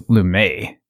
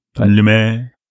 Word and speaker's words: Lumet: I think is how we decided to pronounce his name, correct? – Yeah, I think we did. I think I thought it was Lumet. – Lumet. Sidney Lumet. – I'm wrong. – No, yeah, well, you're wrong.